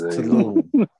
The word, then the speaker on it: Tulum: I had to fix that because I was calling it Tulum in the previous podcast, and I've come to find out that jokes don't work when you can't pronounce the name of the city correctly. So it is Tulum.